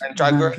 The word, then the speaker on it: Mandragora: How do you say it? It's Mandragora. Mandragora.